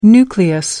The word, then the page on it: nucleus: American English